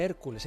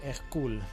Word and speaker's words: Hercule: no se dice Hércules, se dice Hercule, nos dicen las películas, porque es francés, Hercule Poirot, que tantos actores han hecho de Hercule Poirot.